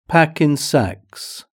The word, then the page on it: pack in sacks: British English